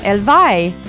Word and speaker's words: Elvy: Ça s'écrit E-L-V-Y. On se demandait si c'était Elvi ou Elvi. C'est Elvi. – Oui, Elvi.